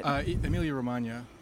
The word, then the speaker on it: Emilia-Romagna: Is that how you pronounce it? – Emilia-Romagna. – Romagna, yeah. Okay, yeah.